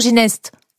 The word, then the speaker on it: Ginest: C'est Castelginet ou Gineste? – Ginest, vous savez, Ginest. Ici on prononce toutes les lettres.